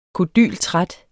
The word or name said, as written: kodyltræt